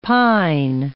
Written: pine

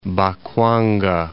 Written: Bakwanga

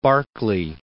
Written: Barkley

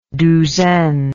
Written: douzaine

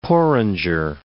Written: porringer

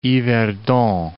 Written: Yverdon

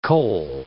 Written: cole

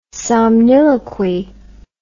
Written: somniloquy

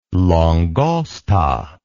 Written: langosta

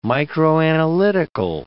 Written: microanalytical

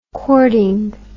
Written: courting

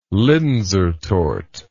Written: linzer torte

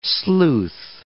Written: sleuth

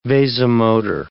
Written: vasomotor

